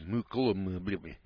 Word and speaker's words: Mokelumne: I can't pronounce this name here. It's Mokelumne. That's the name Hill. It's Mooklumne Hill, California, 95245.